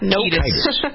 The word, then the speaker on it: Kiedis: I always mispronounce his name, but I think I got it right that time. – Kiedis. – All right. So and it's really misspelled. It should be C-E-I so that the accent would be on the E, vowel after. Well, but a vowel after two vowels together, the second vowel is supposed to emphasize the first one. So if it's K, what I did was pronounce it correctly.